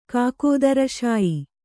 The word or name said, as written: ಕಾಕೋದರಶಾಯಿ